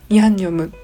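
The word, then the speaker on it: ヤ ン ニ ョ ム (양념): でああとそうあの言い忘れたというか紹介し忘れたんですけれどヤンニョムっていうのはあのあれです漢字がありまして漢字で書くとあの薬に念じるって書いてヤンニョムって読むらしいんですね。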